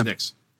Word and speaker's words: USENIX: They presented it at, is it the USENIX conference? Is that how you pronounce it? – Yep, yeah. USENIX.